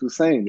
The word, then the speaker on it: Husain: Like, no, my name is Husain.